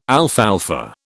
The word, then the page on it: alfalfa: British English